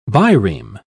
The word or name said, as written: bireme